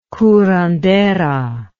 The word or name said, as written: curandera